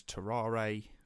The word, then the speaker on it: Tarrare: This is spelt T-A-R-R-A-R-E. And I've heard some people refer to him as Tarrare, but for the most part, I think because he's French, I think it's Tarrare, and I suppose the E and the R and the E is almost like not pronounced.